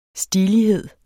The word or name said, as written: STILIGHEDEN